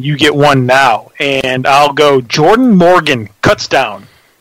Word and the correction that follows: Kutztown: Yeah, it's my backyard, man, and it's pronounced Kutztown.